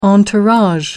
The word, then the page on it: entourage: American English